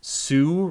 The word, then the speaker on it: Hsu: Subwoofer brands that make an excellent product at a fair price include SVS, SU Research, that's spelled HSU, Klipsch, or Power Sound Audio.